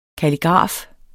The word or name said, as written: kalligraf